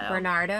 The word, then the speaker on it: Bernardo: Paul Bernardo. – Bernardo.